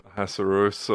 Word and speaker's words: Ahasuerus: How do you say that? – Ahasuerus or Xerxes.